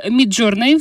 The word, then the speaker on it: Midjourney: Э, что такое Midjourney? Все верно сказала? – Да, это похожая э, сеть, тоже э, нейронная сеть, которая генерирует э, изображение. То есть если чат GPT, он отвечает э, текстом, то э, Midjourney может э, по вашему запросу сгенерировать любое изображение.